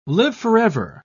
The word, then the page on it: live-forever: British English